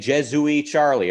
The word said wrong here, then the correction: Je suis Charlie: if I pronounce that correctly. Jesuit Charlie. – Je suis Charlie.